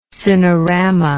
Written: cinerama

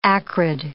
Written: acrid

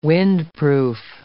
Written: windproof

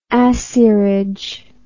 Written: acierage

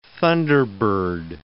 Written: thunderbird

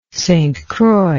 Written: St Croix

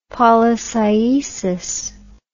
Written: polycyesis

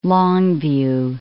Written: Longview

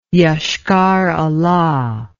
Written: Ioshkar-Ola

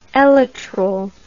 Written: elytral

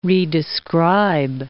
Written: redescribe

